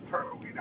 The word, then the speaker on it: Berwyn: The city is B-R-Y-N-A-T. Yes. Is that how you say it, Berwyn? – It's Berwyn, all right. Yeah, Berwyn. It's fine.